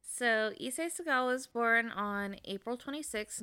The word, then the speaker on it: Issei Sagawa: Anyways, I'm going to be talking about Issei Sagawa. Is that how you would have said it? – Yeah.